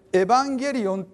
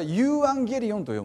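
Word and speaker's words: ユ ーア ンゲ リオ ン (εὐαγγέλιον): でエヴァンゲリオンっていうのはですね、もともとギリシャ語で、福音という意味の言葉なんです。で、正しい読み方っていうか、どれが正しいかっていうのはちょっと正確には言えないんですけど、通常からユーアンゲリオンと読むんです。ユーアンゲリオンと読むんですね。これは現代ギリシャ語ではこれをエヴァンゲリオンと読むんです。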